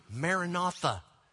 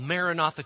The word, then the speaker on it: Maranatha: There are churches called Maranatha Church. Maranatha. We've taken a saying from the New Testament day, and we have pronounced it just as it is spoken in Aramaic, but we've turned it into our own word, Maranatha.